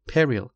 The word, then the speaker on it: peril: peril دو سیلابی استرس، سیلاب اول، یعنی ترس، یعنی خطر.